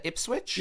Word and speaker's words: Ipswich: Am I saying that right? Is that Ipswich? – Yeah, that's right.